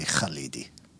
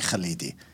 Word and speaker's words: Khalidi: So if you were an Arabic background, you would get that KH sound. It would be kha. It would be Khalidi.